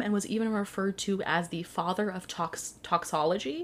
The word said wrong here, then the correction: toxicology: Is that how you say it? Toxicology? – Oh, yeah.